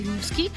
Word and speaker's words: Нивский: У нас в России, в Мурманской области, в удивительном городе Нивский, с ударением на первый слог, чтобы сразу почувствовать мощь и основательность этого места.